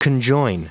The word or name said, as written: conjoin